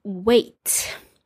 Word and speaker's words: weight: W-E-I-G-H-T. And there's lots of silent letters in here. So weight spelled with six letters. And you don't pronounce six, you pronounce four. It also sounds like that other word we know, wait, which is to wait for someone when you're waiting for something or waiting for someone.